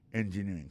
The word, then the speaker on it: engineering: Electrical engineering. C'est sur deuxième syllabe là. Elect là, plus appuyé. Electrical engineering. Engineering, ça, l'accent tombé le même sur avant dernier syllabe là.